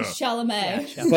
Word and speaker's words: Chalamet: And Bailey said, I would fuck George Costanza before I'd fuck Timothy Chalamet. I don't know if I said that right. – Oh yeah, Chalamet.